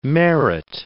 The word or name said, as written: merit